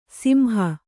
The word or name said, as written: ಸಿಂಹ